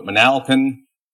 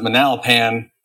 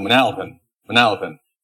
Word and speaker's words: Manalapan: I think I pronounced that one right. I pronounced it Manalapan on a video that I did going back to my old Indiana roots, and I got roasted for it on the internet. So Manalapan.